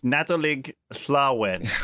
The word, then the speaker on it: Nadolig Llawen: No, close. It's Nadolig Llawen. – Well done on the pronunciation. – Llawen.